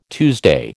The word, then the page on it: Tuesday: American English